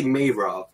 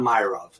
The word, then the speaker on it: Mayrov: Um, you know, the way you pronounce it is the way everyone pronounces it at first, but it's really Mayrov, not Myrov. So um, like the month of May and then Rove.